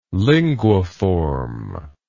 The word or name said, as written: linguiform